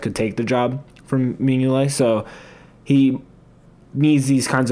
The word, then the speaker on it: Mignolet: because Bruges also has Simon Mignolet, I think is how you pronounce his. – Mignolet. – Thank you, Mignolet.